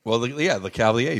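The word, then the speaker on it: Lecavalier: I had trouble with it before, so help me out. Le Cavalier? – Le Cavalier. Vinny Le Cavalier.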